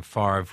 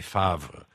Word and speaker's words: Favre: Another person wants to know why Brett Favre pronounces his name Favre when it should be Favre.